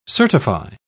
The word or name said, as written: certify